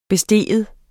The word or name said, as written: bestige